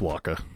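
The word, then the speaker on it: Wakka: It's Waka. – Okay. – Like the Shakira song.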